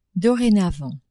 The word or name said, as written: dorénavant